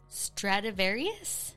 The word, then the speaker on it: Stradivarius: Stradivarius? – That actually sounds perfectly correct.